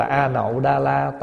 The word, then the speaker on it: A-Nậu-Đa-La: Lại có trăm nghìn người phát tâm A-Lốc-Đa-La Tam Diệu Tam Bồ Đề. Biết cái chữ này không? Mình thường đọc là A-Nậu-Đa-La Tam Diệu Tam Bồ Đề đó, nghĩa là vô thượng chánh đẳng chánh giác.